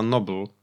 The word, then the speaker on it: noble: E, nazwa pochodzi od angielskiego słowa ignoble, niegodziwy, e, które jest przeciwieństwem słowa noble, wymawianego właśnie tak samo jak słowo Nobel w znaczeniu Nagrody Nobla.